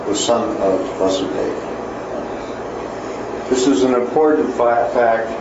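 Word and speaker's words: Vāsudeva: So here he wants to say that this, that's why he's saying this Vasudeva with the long A, here denotes the son of Vasudeva, therefore it means Sri Krishna.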